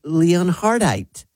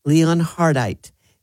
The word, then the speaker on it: Leonhardite: And the dirty word of the day is Leonhardite. Leonhardite. That's spelled L-E-O-N-H-A-R-D-I-T-E.